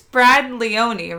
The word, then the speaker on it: Leone: It's Brad Leone, right? – Yes.